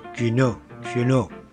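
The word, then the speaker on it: Quéniaux: se escribe Kenny Aux y se pronunciará Cuneau.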